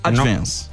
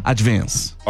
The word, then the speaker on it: advance: É um A puxando pro E, ó. Que vê, ó, a diferença, tá. Ó. Advance. Aí você falou advance, mas ó, é um E puxando, ó. É advance. – Advance.